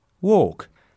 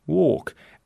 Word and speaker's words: walk: So, the word walk is not pronounced correctly. That word is walk.